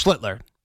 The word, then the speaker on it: Schlittler: Well, I think you said his name wrong. It's okay. It's Schlittler. – Schlittler, I'm sorry.